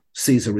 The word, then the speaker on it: SZA: This is All the Stars by Kendrick Lamar and Caesar. Caesar is spelled S-Z-A, for those of you who don't know.